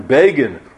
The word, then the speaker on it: Begin: Most infamously, remember, Time magazine wrote about him, Begin rhymes with Fagin,